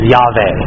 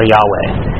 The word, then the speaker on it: Yahweh: But the way we normally pronounce it today, based off of some pretty good reasoning, is Yahweh, or Yahweh.